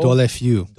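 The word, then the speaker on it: Deulofeu: You say his name better than I do. Gerard Delafoe? – Delafeu. Or Delafeu, yeah. – Sorry, my pronunciation is a little bit wrong.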